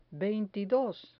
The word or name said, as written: veintidos